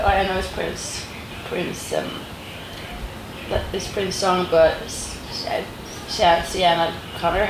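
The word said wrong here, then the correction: Sinéad O'Connor: how do you say it in English? – Sinead O'Connor, yeah.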